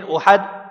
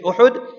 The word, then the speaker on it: Uhud: And he positioned the army in such a way, Madina Munawwara was in front, and Uhud, many people say Uhud, correct pronunciation is Uhud, kept the Mount Uhud behind.